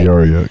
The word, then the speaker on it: Peoria: And there is one in Peoria. I hope I'm pronouncing it right. – Peoria, yeah.